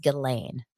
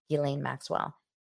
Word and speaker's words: Ghislaine: Oh, it's pronounced Ghislaine. Thank you so much. Ghislaine Maxwell.